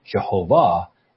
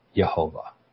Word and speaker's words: Yehovah: should be Jehovah and not Yehovah. Okay, so this is the statement. Now, I contend that, in fact, the yod is the correct pronunciation, or the Y sound is the correct pronunciation.